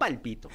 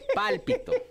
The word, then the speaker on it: Pálpito: No palpito, no. Pálpito. Con acento en la ¿No? De palpitar. o sea, no, es de, no, es de palpitar.